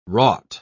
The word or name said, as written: wrought